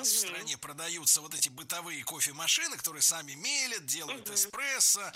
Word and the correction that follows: мелят: Мелят – это неверно. Правильно, мелят, мелит, мелим, мелиш. Ударение в этом слове всегда стоит за корнем, а корень остается безударным.